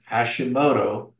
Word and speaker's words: Hashimoto: That rhymes with Yoda, and my last name is Hashimoto, like the thyroid disease.